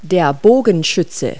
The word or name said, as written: archer